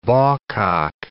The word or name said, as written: bawcock